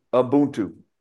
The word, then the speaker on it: Ubuntu: It's Ubuntu.